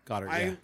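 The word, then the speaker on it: Goedert: Goddard. I knew I was saying that wrong. Goddard, I want to lean towards Gerald Everett because of the matchup and because of injuries.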